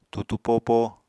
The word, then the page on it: tutu popo: apatani